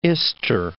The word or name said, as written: Ister